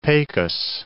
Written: Pecos

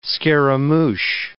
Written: scaramouch

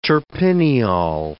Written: terpineol

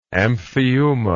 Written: amphiuma

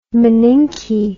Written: maninke

